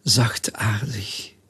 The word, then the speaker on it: zachtaardig: The word is zachtaardig. Zacht means soft. And aardig means family of soft.